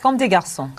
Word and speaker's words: Comme des Garçons: How do you say it? – Comme des Garçons. – Comme des Garçons.